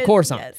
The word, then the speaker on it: Coruscant: I was like, no, the C is just pronounced S. So Coruscant.